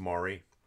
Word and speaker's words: Mari: I'm pronouncing your name wrong. It's Mari, not Mary.